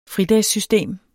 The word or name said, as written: fridagssystem